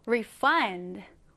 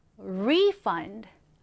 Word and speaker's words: refund: refund가 동사로 쓰일 때, 즉 환불하다라는 의미로 쓰일 때는 강세가 뒤에 들어갑니다. refund, refund 이렇게요. 그런데 명사로 쓰일 때는요. 앞에 강세가 들어가게 됩니다. 그러면 refund, refund가 되겠죠? 자, 여러분, 환불하다라는 의미일 때는 refund, 환불일 때는 refund 이렇게 발음하시면 됩니다. 자, 오른쪽에 같이 한번 스펠링 체크하면서 써볼게요. refund, R-E-F-U-N-D, refund.